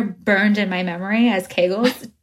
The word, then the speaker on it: Kegels: I was speaking to her and I was telling her about it, and I was like, oh, and they do Kegels for you. And she's like, it's actually Kegels.